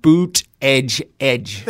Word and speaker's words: Buttigieg: One thing I like about Donald Trump is the way that he says Buttigieg's name, like boot, edge, edge. – They call him Mayor Pete because nobody can pronounce his last name. Here's what you do. Boot, edge, edge.